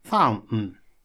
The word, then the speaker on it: fountain: Ну вот, имейте в виду, что правильное все-таки произношение fountain.